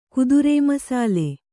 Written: ಕುದುರೇಮಸಾಲೆ

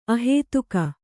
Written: ಅಹೇತುಕ

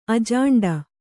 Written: ಅಜಾಂಡ

